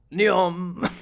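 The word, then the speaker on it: NEOM: Ne- oh, yeah, neon. Yeah. – Neon.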